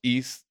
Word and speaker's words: Ys: It's spelled a Y, but it's pronounced East. It was a Japanese joint that was released for TurboGrafx, and it was ill as fuck, and I love that joint.